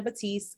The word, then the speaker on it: Batteast: They can find me under Shanna Batteast. I know my name is interesting spelled, or so I'll spell it as B-A-T-T-E-A-S-T.